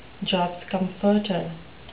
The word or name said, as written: jobs comforter